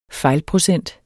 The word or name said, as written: fejlprocent